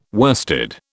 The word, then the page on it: worsted: British English